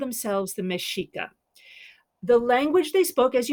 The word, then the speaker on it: Mexica: And Aztec, I guess, is a European term for the Mexica people, when Mexica spelled like Mexica, but it's pronounced Mexica.